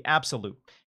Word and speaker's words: Abxylute: I've been spending some time with a cloud gaming device called the Absolute. It's spelled A-B-X-Y-L-U-T-E.